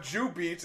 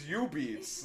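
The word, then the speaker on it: jubeat: Today I learned that in the Japanese language, it's not Jubeats, it's U-beats.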